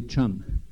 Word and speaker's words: chum: Et tous les hommes sont des chums. Ça s'écrit C-H-U-M, mais ça se prononce comme s'il y avait un T au début, un chum.